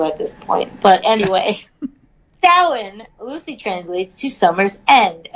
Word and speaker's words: Samhain: It's pronounced Samhain, but it's spelled S-A-M-H-A-I-N.